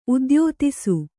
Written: ಉದ್ಯೋತಿಸು

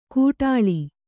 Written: ಕೂಟಾಳಿ

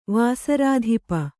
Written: ವಾಸರಾಧಿಪ

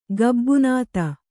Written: ಗಬ್ಬುನಾತ

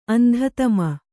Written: ಅಂಧತಮ

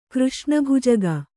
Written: ಕೃಷ್ಣಭುಜಗ

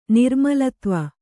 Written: ನಿರ್ಮಲತ್ವ

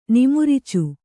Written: ನಿಮಿರ್ಚು

